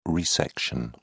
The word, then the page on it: resection: British English